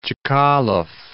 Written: Chkalov

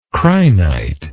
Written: crinite

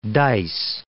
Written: dice

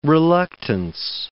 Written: reluctance

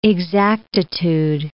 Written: exactitude

